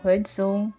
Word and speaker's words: Hudson: Ele é protagonizado pela Kate Hudson, Hudson é assim que se pronuncia, eu nunca acerto.